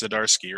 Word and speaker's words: Zdarsky: Speaking of firing on all cylinders, I saw something come across the Marvel subreddit today about none other than, I'm going to mispronounce this, but I imagine it's Zdarsky, right? – Yeah, nailed it.